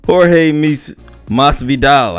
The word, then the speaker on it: Jorge Masvidal: Jorge Masvidal. I think that's how you say it. I think I got his name wrong last time, but I said it right this time.